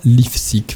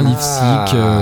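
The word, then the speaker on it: Lifszyc: Julien Lifsic, c'est aussi moi, et ça s'écrit L-I-F comme France, S comme Séverine, Z-Y. Voilà, c'est un nom polonais, c'est un peu chiant. Mais il y a Lifsic dedans. Lifsic, Lifsic, Life Music.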